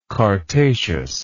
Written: chartaceous

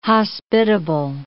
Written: hospitable

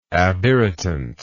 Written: abirritant

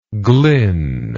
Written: glyn

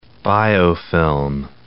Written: biofilm

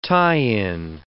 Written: tie-in

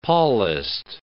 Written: Paulist

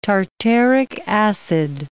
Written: tartaric acid

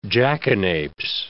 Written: jackanapes

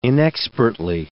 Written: inexpertly

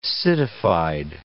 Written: citified

